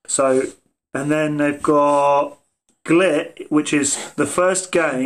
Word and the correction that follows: Gylt: So Glit is spelled GYLT. – Yeah. Oh, guilt. Sorry.